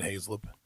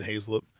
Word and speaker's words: Hazlip: If I'm trying to tell somebody how to pronounce it and they look at me, I'm like Hazlip. They're like Hazelwood. I'm like, no, still just Hazlip.